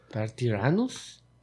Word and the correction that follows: Darth Tyrannus: Era Darth Tyrannus.